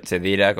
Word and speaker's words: Khedira: Sí, Khedira con CH, Khedira, como se pronuncia en Marruecos.